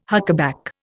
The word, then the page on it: huckaback: Indian